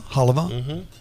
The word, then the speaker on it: halva: Chocolate sesame, hava, is that how you say it? Hava? Chopped hava? – Yeah, halva.